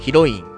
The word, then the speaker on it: 彼女: だからどれを押すかっていうのも何とも言えなくて、まあ、ただその中でも押すんであれば、第 3 位、冴えない彼女の育て方、フラットね、彼女と書いてヒロインって読むんだけど、略称はね、まあ、どうでもいいですかね、あの略称は冴えかのなんだよね。でも正式名称は冴えないヒロインの育て方と、ね、なんともわけわかんないんですけど、これの第 2 期のやつ、これが第 3 位です。